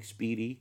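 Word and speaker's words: spiedie: So Speedy, I'm going to try to spell it. S-P-I-E-D-I-E, I think. Speedy.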